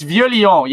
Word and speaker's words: Vieux Lyon: Okay, so yeah, ending this off at number one, it's the ZX5000, and I'm going to butcher this, Vuxlion. – Oh, man. – I said I was going to butcher it. – It's Vieux Lyon, yeah. It's Vieux Lyon, which is like old Lyon. Vieux is old.